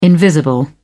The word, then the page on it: invisible: British English